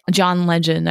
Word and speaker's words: Legend: John Legend. I'm saying his name right, Jason? – Yeah, yeah, it's John Legend.